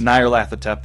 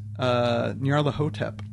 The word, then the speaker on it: Nyarlathotep: Nyarlathotep, that's how we're choosing to pronounce it, although I'm sure there's more Egyptian ways to say it. – Andrew Lehman pronounces it Nyarlathotep.